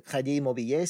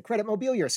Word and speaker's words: Crédit Mobilier: So this actually leads us into the actual scandal, which is the Crédit Mobilier scandal, or in American, it's the Credit Mobilier scandal. I have listened to a lot of tape of how people pronounce this, and Credit Mobilier is the least distracting. So it's incorrect, but we're going to go with that.